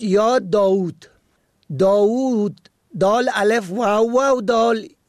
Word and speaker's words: داوود: واژه ها یا کلماتی مانند داوود با دو واو، کاووس با دو واو و مانند آنها که با اشبا تلفظ می شوند در نگرش پارسی با دو واو، یکی به گونه صامت و دیگری به گونه مصوت بلند